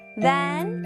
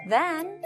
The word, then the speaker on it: then: Then sounds like ran. Man, plan, ran, and then. Then, 무엇무엇보다. Now, then 할 때, 발음할 때, 혀끝이 앞으로 나왔다가 들어가야 돼요. Don't forget.